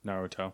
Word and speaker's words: Naruto: Naruto, am I wrong on that pronunciation? – That's right. – Naruto, Naruto? – Naruto.